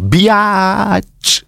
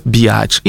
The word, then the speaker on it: bitch: W każdym razie ja jako raper młodszego pokolenia chcę wspomnieć, jemu zawdzięczamy, to jemu, to Too Shortowi zawdzięczamy odmianę wymowy słowa B-I-T-C-H jako biacz, co powstało być może dla hecy, a być może po to, żeby cenzura trudniej miała w zdejmowaniu tego słówka niecenzuralnego zazwyczaj. I tak już zostało, że czasami się to wymawia właśnie per bijać.